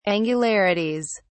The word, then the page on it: angularities: British English